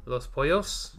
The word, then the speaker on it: Los Pollos: Los Polos person. – Los Pollos? – Yeah. – Two L's is pronounced as a yeah.